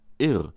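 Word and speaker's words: Yrr: Er nennt die Irr in einem auf Englisch geführten Interview Irr, ohne starke Betonung. Ebenso Stefan Kaminski im Hörbuch. Das Irr ist nicht aufdringlich.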